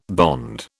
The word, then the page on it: bond: British English